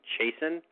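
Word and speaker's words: Chasin: You know, it looks like Chasen, but it's spelled Chasen, as if, you know, bad grammar, C-H-A-S-I-N, I'm chasing someone.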